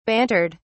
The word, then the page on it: bantered: British English